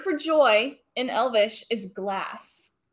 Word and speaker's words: glass: It's definitely spelled that way, and from what I understand, it's pronounced that way as well, because A is pronounced ah. – Yeah. – So, glass. – Gloss.